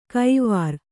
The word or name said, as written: ಕೈವಾರ್